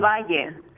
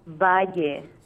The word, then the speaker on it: Valle: Del Valle. Soy del Valle. – Okay, say it again. – Soy. – Yes, de. – Del. – Del. – Soy del. – Oh, spell that last word. – Valle. – Valle. – V-A-L-L-E. Valle. – V-A-L-L-E.